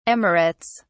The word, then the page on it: emirates: British English